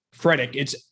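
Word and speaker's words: Fredette: Freddick. It's Fred and then E-T-T-E.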